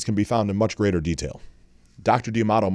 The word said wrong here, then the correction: D'Adamo: might have been the founder of this, and that's D-A-D-A-M-O. So D'Adamo. I'm actually butchering his name this whole time.